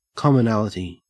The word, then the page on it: commonality: Australian English